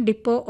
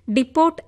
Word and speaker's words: depot: Armed vehicles and ammunition ഡിപ്പോ of India എന്നാണ് ഉത്തരം. അതായത് DEPOT എന്ന വാക്കിന്റെ ഉച്ചാരണം ഡിപ്പോട്ട് എന്നാണ് പറഞ്ഞത്. എന്നാൽ ഒടുവിലത്തെ ടി നിശബ്ദമാണ്.